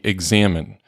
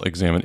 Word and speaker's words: examen: It's not spelled like a normal examen. It's E-X-A-M-E-N. – And it's kind of like the Lectio or Lectio Divina. Is it examen or is it examen?